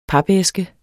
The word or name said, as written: papæske